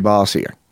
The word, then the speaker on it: Bossier: No, it would be – it's Louisiana. It would be Bossier. It'd be like the redneck. You don't say it French there.